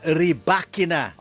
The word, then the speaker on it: Rybakina: And yes, it's pronounced Elena Rybakina. Hindi Rybakina.